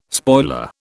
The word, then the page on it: spoiler: British English